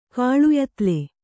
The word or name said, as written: ಕಾಳುಯತ್ಲೆ